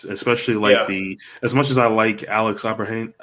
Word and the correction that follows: Abrahantes: How do you say his name? – Abrahantes. – Yeah, Alex Abrahantes.